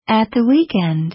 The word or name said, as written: at the weekend